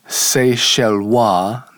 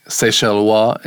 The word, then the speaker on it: Seychellois: And we see here there's a variety of Seychellois. That O-I-S on the end of a French word is often pronounced wa.